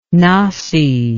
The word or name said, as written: nasi